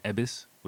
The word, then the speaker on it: Yebisu: I'm drinking an Ebisu, which is spelled Y-E-B-I-S-U.